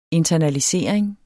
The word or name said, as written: internalisering